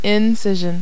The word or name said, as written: incision